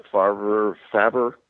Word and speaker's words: Favre: You know, we still had to learn how to pronounce his name. Brett Favre, Favre.